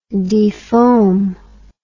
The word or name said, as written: defoamer